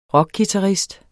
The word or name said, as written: rockguitarist